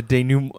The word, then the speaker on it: denouement: as a denouement. Good good pronunciation on that one.